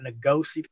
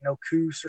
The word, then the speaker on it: Nokuse: And then they call it Ngosi Plantation, is how it's pronounced. It looks like the way it's spelled, Nokuse, looks like no coos or something, but it's pronounced Ngosi Plantation.